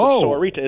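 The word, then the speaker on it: Sahuarita: And I have to remind myself that Sahorita is four syllables.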